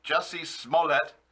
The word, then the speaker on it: Jussie Smollett: J-U-S-S. I think it's Jussie Smollett. Anyway, his testimony was an, quote, unparalleled disaster. Jury will see through his, quote, ruse and convict him, say the experts. You don't say. Jussie Smollett